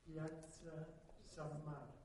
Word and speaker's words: Piazza San Marco: Piazza San Marco.